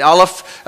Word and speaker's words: Aleph: So this is the first letter alphabet, Aleph, I'm not a Hebrew scholar, so I'll say that wrong. Forgive me, Michael. Okay, very good. All right, and so I got a thumbs up from the Hebrew scholar back there.